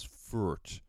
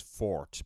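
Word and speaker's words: Foort: I thought he was Furt. Actually, he's Fort, because the British Pathé archives have film of him, and that's how the announcer refers to him.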